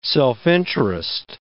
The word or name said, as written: self-interest